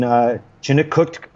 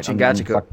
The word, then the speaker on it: Chingachgook: But, you know, like when Duncan and Hawkeye have that look, every significant dramatic moment in the film, I mean, obviously between Chingachgook, I can't say it right. – Chingachgook.